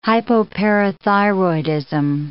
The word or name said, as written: hypoparathyroidism